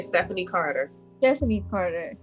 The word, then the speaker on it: Stephanie Carter: Stephanie Carter.